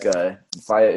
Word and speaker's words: Faia: Faia? – Yeah, that's perfect, man. Like, Faia is correct. Yeah, we wanted it to sound like fire.